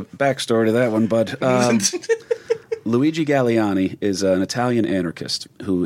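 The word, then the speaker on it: Galleani: But they're both believed to be, and this is where we're going to need you, the pronunciation of this one, I will screw it up. Both are believed to be followers of a man by the name of Luigi Galliani. – That's correct.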